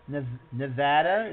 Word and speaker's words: Nevada: Continued success with, I'm trying to say it correctly here, Nevada. Nevada, is that it? – You did it.